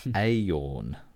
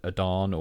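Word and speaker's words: Aodhán: So within their comments, they've said, by the way, name is pronounced Ayorn. So there is it. I'll spell the name, because I think in the past I've pronounced it as Adan, or because it's A-O-D-H. There's an accent over the A and then an N.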